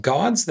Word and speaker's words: GAWDS: GAWDS. That's G A W D S, which is why I say it like that.